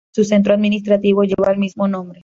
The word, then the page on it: mismo: ispanu